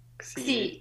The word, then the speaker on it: Xi: nazywa się plaża Si, obok niej jest taka pani Ci. Xi. Pisze się X i I.